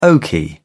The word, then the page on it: oaky: British English